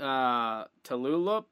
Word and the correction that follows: Tulalip: Does that sound right? I said it wrong. I knew I would. – Yeah, I'm part Native American and I'm from Seattle. It's Tulalip.